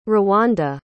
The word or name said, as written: rwanda